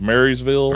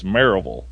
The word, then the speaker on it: Marysville: It's spelled Marysville. – Right. – Which probably most everybody would say is Marysville. – Right. – But around here, it's Maryville.